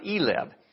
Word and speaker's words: ILEB: Those initials are ILEB, and in Spanish, that's pronounced ELEB.